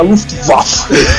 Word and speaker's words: Luftwaffe: Então eu fico falando, é a Luftwaffe.